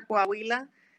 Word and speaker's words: Coahuila: Mucha gente no puede pronunciarlo, pero se pronuncia Coahuila.